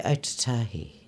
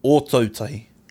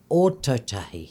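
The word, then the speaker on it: Ōtautahi: Okay, so next to Motueka, As I can say Otatahi. Do I say that properly? – No. – No. – Good, that's what we need. – Yes. – Honesty. No. – Again. – Ototahi.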